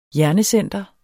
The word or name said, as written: hjernecenter